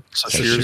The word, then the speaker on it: Sasheer: How do you say Sasheer? – Sasheer Zamata.